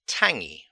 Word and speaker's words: tangy: Okay, next, tangy. And there's this pronunciation here important. T-A-N-G-Y is the spelling. Tang, like T-A-N-G-E, tangy with a Y on the end.